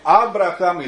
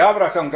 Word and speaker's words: Abraham: Ljudi su me pitali, sada mi je neko postavio pitanje na internetu, zašto kažeš Abraham, a ne Avram? Zato što je Bog, srpski se izgovara Avram, i to je u redu, hrvatski se izgovara Abram, i to je u redu, ali Bog je promijenio njegovo ime i nije ni Avram, ni Abram, nego je dodao nastava Ham. I on je Abraham ili Avraham, kako hoćete, ali to Ham mora biti nazad, zato što je on otac vernika celoga sveta.